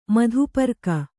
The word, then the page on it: ಮಧುಪರ್ಕ: madhu parka